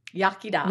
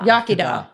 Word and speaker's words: Iechyd da: How do you say cheers, but uh, Iechyd da. Yakida.